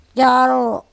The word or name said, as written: cOro